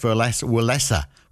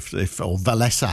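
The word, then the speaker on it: Wałęsa: Lech Walesa was the guy, or Walesa, I think is how you pronounce it.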